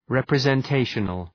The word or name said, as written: representational